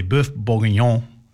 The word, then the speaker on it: bœuf bourguignon: They're going to bring French cuisine from raclette to, I'm going to say it properly, bœuf bourguignon, which is great.